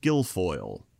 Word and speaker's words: Guilfoyle: Herman Cain and Kimberly Guilfoyle.